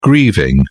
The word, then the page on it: grieving: British English